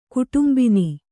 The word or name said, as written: ಕುಟುಂಬಿನಿ